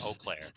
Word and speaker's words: Eau Claire: No, that's the town I live in, and it's pronounced Eau Claire.